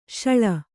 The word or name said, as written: ಶಾಳ